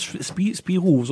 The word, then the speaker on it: Spirou: Wie spricht man denn aus? Spirou, so französisch.